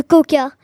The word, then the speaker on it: quokka: Et la mignonitude, le coca. Et ce n'est pas comme la boisson, ça s'écrit Q-U-O-K-K-A. Donc ce n'est pas très pareil.